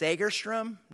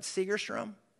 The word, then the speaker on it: Segerstrom: Uh, by the way, church, it's pronounced Sagerstrom, not Segerstrom.